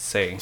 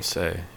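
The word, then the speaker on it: Hsieh: Tony, I think it's pronounced Say. – Say.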